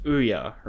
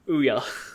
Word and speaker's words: Ouya: Just to be fair, you know it's pronounced Ouya, right? – Nope, sure don't. – Ouya.